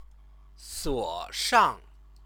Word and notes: suoshang: Chinese

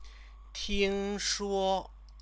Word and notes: tingshuo: chinese

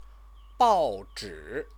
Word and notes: baozhi: Chinese

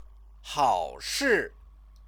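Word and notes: 好事: hăoshì

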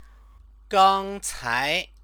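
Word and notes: 刚才: gāngcái

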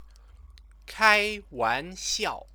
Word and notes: kaiwanxiao: Chinese

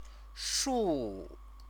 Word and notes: shu: chinese

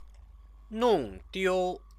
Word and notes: nongdiu: Chinese

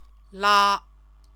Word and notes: la: Chinese

